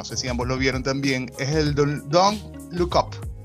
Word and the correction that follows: Don't Look Up: A ver, lo dije bien, Don't Look Up.